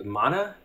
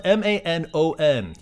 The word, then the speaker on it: Manon: I always thought it was, maybe I did, maybe Mana, maybe? What was it? – It's spelled M-A-N-O-N. Yeah, it sounds like Mana, the way they were pronouncing it, but it's actually Man-on.